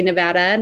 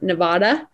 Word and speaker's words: Nevada: And we say, again, another state that people sometimes mispronounce, we say Nevada, not Nevada.